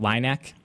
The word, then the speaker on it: LINAC: Now, I hope I'm saying that right, LINAC and not LINAC? – LINAC is correct.